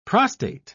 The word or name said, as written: prostate